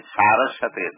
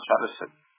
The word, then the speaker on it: Saraswati: You should pronounce it Saraswati, not Saraswati.